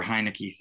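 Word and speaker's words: Heinicke: It feels like Taylor Heineke. Why can't I spell his name? Say his name. – Taylor Heineke.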